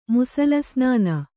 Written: ಮುಸಲಸ್ನಾನ

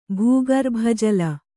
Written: ಭೂಗರ್ಭಜಲ

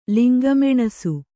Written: ಲಿಂಗಮೆಣಸು

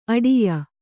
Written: ಆಢ್ಯೆ

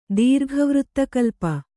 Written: ದೀರ್ಘವೃತ್ತಕಲ್ಪ

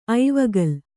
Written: ಐವಗಲ್

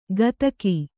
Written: ಗದಕಿ

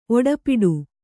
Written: ಒಡಪಾಟ

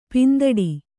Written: ಪಿಂದೊಡೆ

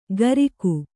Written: ಕರಿಕು

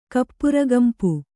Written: ಕಪ್ಪುರಗಂಪು

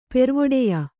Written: ಪೆರ್ವೊಡೆಯ

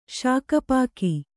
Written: ಶಾಕಪಾಕಿ

